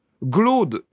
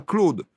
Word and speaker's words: Claude: Le C se prononce quelquefois comme un G. Ainsi, on prononce segret et non pas secret. Second et non pas second. Glaude et non pas Claude. Quoique dans l'écriture, on doit absolument retenir le C. Et d'un seul coup, on découvre qu'au XVIIe siècle, on ne disait pas Claude, mais Glaude, et qu'on ne disait pas secret, mais segret.